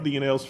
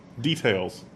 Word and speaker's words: details: 92 million account DNLs for the DNA testing service MyHeritage. – DNLs? You're feeling it. – What now? What did I say? Did I say it wrong? – DNLs. – Details.